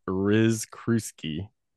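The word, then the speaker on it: Rzykruski: Then at the school, we get this new science teacher, which I've been having a hard time saying the name, but it's Yeah. – pronounced Riz Kruski.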